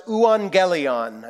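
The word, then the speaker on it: euangelion (εὐαγγέλιον): It's pronounced euangelion. I know it doesn't really look like that, but that's how Greek letters are pronounced.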